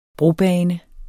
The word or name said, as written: brobane